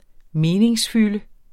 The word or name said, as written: meningsfylde